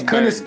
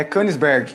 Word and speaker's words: Königsberg: É Königsberg. É, Königsberg.